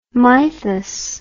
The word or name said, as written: mythus